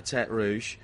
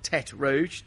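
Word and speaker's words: Tertre Rouge: Then you come down to Turt Rouge, the Red Hill, as it is. Turt Rouge, not Turt Rouge, not Redhead, Red Hill. Yeah, no, you said it right. A lot of people don't.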